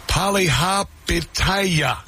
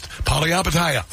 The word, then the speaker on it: Palihapitiya: This is Chamath, I'm going to try and pronounce this, guys, Palihapitiya. Nailed it. Palihapitiya.